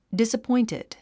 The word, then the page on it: disappointed: dìsəpɔ’intid; ディサポインテッド